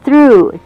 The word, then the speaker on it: through: Woodchuck, C-H-U-C-K, chuck, woodchuck, 啄木鸟. 这种鸟不太常见, huh? Goes out through the other, through, T-H-R-O-U-G-H. 最后的G-H在这边是 silent word, 不发音.